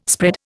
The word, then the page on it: spread: Indian